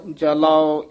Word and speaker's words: जलाओ: हमै ममता जल बलाओ लोभ जलाओ अभिमान। – ए बलाओ जलाओ इस तरह उच्चारण करना है, बलो जलो नहीं कहना।